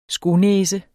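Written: skonæse

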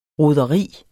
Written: roderi

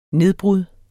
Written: nedbrud

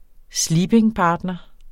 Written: SLEEPINGPARTNEREN

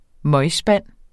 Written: møgspand

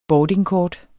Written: boardingkort

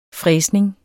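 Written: FRÆSNING

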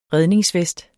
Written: REDNINGSVEST

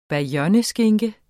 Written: BAYONNESKINKE

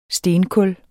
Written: stenkul

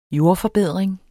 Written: jordforbedring

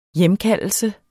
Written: hjemkaldelse

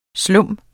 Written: slum

